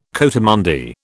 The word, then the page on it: coatimundi: British English